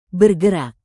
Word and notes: bergerak: Indonesian

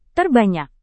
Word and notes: terbanyak: indonesian

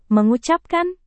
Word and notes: mengucapkan: Indonesian

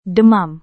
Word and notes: demam: Indonesian

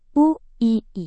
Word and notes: uii: Indonesian

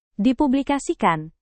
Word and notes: dipublikasikan: Indonesian

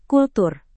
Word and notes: kultur: indonesian

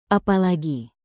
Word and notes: apalagi: indonesian

